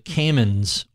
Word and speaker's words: Cayman: To circle back to the Caymans, or the way I love the way the locals pronounce it, Cayman,